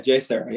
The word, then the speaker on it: JSerra: Okay, JSerra High School. That's how you say it. – Yeah, JSerra. Yeah, from San Juan Capistrano.